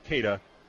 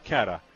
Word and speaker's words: Cada: And by the way, it is pronounced Cada, not Cada.